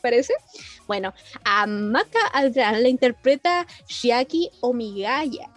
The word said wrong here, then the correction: Omigawa: Omigawa, perdón, Omigawa, me cuesta pronunciar la, la W.